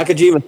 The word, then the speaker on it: Nakajima: Haruo Nakajima. I can get the last name. Not sure about the first one. – Nakajima.